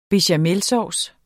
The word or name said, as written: bechamelsauce